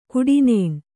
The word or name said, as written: ಕಟ್ಟನ್ನ